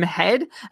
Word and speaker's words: Heed: Heed, or as I think our Swedish friends have told us, it's pronounced Tim Head.